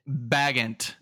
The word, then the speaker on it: Bagent: Bagent.